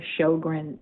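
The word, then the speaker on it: Sjögren: I have a disease called Sjogren's disease, named after a Swedish doctor named Dr. Sjogren, Sjogren, and the J is pronounced like an H.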